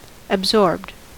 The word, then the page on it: absorbed: American English